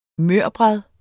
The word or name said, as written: mørbrad